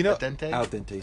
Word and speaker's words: al dente: It's a dente, sir. Is that how you say it? – A dente? Al dente, sir.